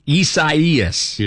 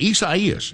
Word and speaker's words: Isaias: Isaias. – Is correct. – Is that, I had to think about it. – That's how I've been doing it, Isaias.